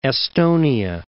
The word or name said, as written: Estonia